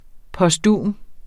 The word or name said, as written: posthum